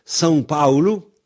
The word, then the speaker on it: São Paulo: Par exemple, São Paulo, là, au Brésil, c'est pas du tout São Paulo, c'est São Paulo. Et ce sang, là, c'est S-A tilde O. Alors, autrement dit, c'est pas Sao, mais sans O. Ça indique que le A se prononce en.